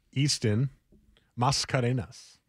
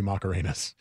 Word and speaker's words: Easton Mascarenas: Easton Mascarenas. – Macarena. – And I asked Andrew Nemec, please tell me the S is silent because I want his name to be Macarenas. It's not.